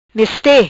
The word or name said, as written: Meste